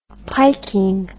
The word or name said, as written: piking